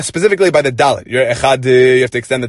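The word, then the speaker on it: echad (אחד): But that doesn't mean that you can cut off the chet, meaning you have to say echad, right? Not echad, just to rush through the chet so you can get to the dalet faster. You have to still pronounce it properly.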